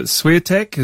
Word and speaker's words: Swiatek: And in the ladies, it looks like, is it Swiatek?